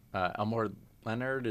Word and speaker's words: Elmore Leonard: Elmore Leonard, is that, am I saying his name correctly? – Yeah.